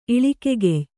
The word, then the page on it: ಇಳಿಕೆಗೆಯ್: kannada